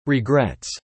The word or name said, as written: regrets